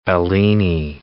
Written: Bellini

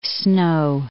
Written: Snow Mountains

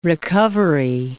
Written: recovery

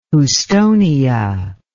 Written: houstonia

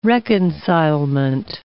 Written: reconcilement

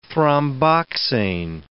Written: thromboxane

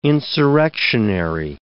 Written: insurrectionary

